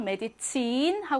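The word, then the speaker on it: Medizin: 여기도 강세 받는 모음 뒤에 자음 하나죠? 메디친 하고 길게 발음하고요.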